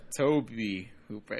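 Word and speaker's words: Tobe Hooper: Yeah, Toby Hooper. It sounds weird to me. I'm sorry. I know that's the right way to pronounce it now, but I just I don't get it.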